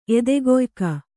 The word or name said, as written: ಎದೆಗೊಯಿಕ